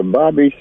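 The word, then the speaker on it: Bobbie: Every Tuesday morning, it's the Bobby Spiderweb Tuesday Morning Blues Show.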